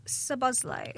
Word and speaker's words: Szoboszlai: Well, Dominic Szoboszlai.